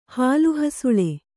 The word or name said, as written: ಹಾಲುಹಸುಳೆ